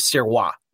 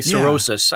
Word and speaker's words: Sirois: He hands it back to me and the way to actually pronounce the last name is Sirois. That's the French Canadian way of saying it. So, and I'm not used to hearing that at all. I'm used to hearing cirrhosis. Yeah.